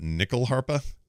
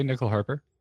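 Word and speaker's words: nyckelharpa: By the way, the stringed instrument used is called a Swedish, I'm not going to say this right, but nickel harpa? – I would say nickel harper. – N-Y-C-K-E-L harpa. So probably nickel, I guess.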